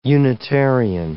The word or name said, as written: Unitarian